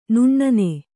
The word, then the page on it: ನನ್ನಿ: kannada